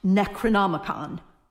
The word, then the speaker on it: Necronomicon: It's pronounced Necronomicon.